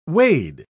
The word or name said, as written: wade